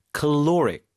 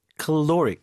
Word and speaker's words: caloric: 근데 이제 여러분 들을 때 caloric, caloric 이게 칼로리하고 너무 발음이 다르잖아요.